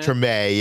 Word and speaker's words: Treme: So he The Wire, I'm pronouncing that wrong, right? – It's Treme, isn't it? – Treme, yes.